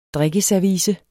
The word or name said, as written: drikkeservice